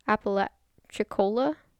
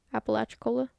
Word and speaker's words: Apalachicola: Also, three other streams merge to form the Gosh, I looked up how to freaking pronounce this word. Apalachicola River? Apalachicola? – Apalachicola. Sounds right.